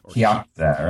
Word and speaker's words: Kyakhta: and I'm going to butcher the pronunciation, Kyakhta. – Kyakhta, right. – Kyakhta.